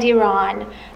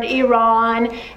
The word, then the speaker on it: Iran: how to properly pronounce the word Iran, Iran, Iran where? No, it's Iran, E, no, no, E.